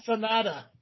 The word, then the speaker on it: Sonata: Oh, it's an Odasan. It's a Hyundai Odasan. – Oda Sonata.